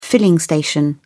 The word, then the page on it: filling station: British English